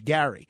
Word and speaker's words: Gerry: He actually pronounced it Elbridge Gary.